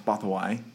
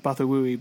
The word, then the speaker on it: Bothawui: Yeah, that's the that's the, that's how it's pronounced Bothawai, um, but it's spelled the same.